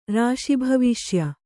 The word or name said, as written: ರಾಶಿಭವಿಷ್ಯ